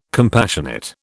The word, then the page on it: compassionate: British English